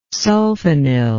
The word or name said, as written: sulfinyl